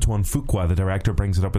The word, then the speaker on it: Fuqua: Antoine Fuqua, it's lovely to meet you.